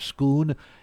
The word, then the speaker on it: Scone: The word the locals have for India, pronounced Bharat, and that ancient slab of rock King Charles was crowned upon, is known as the Stone of Scone, even though it's spelled like scone, S-C-O-N-E.